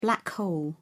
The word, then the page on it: black hole: British English